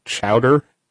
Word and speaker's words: Chaudoir: Now, didn't they have a thing early on where I listened to it where he said that they don't say what Tom's last name is because it looks like Chaudoir because it's French or something like that, but it's actually pronounced like Chowder.